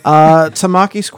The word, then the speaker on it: Tamaki: Tamaki's quirk grants him the ability to do what? – Tamaki? Did you pronounce that name right? – I did, but...